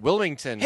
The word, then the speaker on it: Wilmington: They have Summer Showdown 2014 in Wilmington, is it? North Carolina? Is that Wilmington. – How you say it? Wilmington.